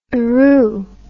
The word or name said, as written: eroo